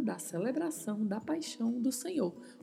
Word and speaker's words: señor: Atenção para a pronúncia dessa letra N, a letra N com o tio em cima, que tem o som de NH, e o vibrato do R ao final. Senhor, Senhor.